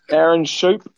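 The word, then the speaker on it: Schoop: I have no idea how you pronounce that. Is it Shoop? – Aaron Shoop.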